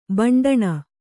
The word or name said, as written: ಭಂಡಣ